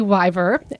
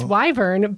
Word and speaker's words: wyvern: It used to be wyver, and then it turned to wyvern. So, in our episode, it's wyvern, but you nailed it on the on the original.